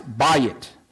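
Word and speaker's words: bayit (בית): And it's pronounced bayit. And when I took a little bit of Hebrew, not very much, but when I took a little bit, that was an easy one for me to remember because I've been in the real estate business a long time. And when I think of a house, I think of buying the house.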